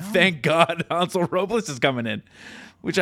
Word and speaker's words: Hansel: It's Hansel Robles. – I'm going with Hansel this year. – Yeah. – Soft A.